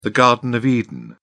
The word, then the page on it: garden of eden: British English